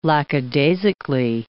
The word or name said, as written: lackadaisically